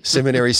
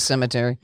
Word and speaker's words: seminary: and I said it right this time, seminary school. – He says cemetery. – Yeah, cemetery.